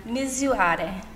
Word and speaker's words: Misurare: Misurare. So it means to measure in Italian.